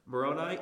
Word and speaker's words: Moroni: Moroni 8:18 says, yeah, I got it. I pronounced it right this time. I got corrected last time by a comment on the live chat.